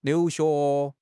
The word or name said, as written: liu2 xue2